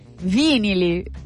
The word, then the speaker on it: vinili: Vinili, con l'accento sulla prima, vinili a caso.